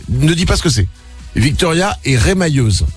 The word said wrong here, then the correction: remailleuse: C'est ce que c'est? – Rémailleuse. C'est ça, rapport avec les mailles. – Remailleuse. – On dit, ça se prononce pas comme ça? Remailleuse? – Remailleuse, oui. – Ah, remailleuse, pardon.